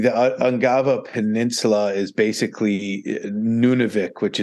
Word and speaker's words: Ungava: Ungava.